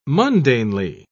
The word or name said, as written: mundanely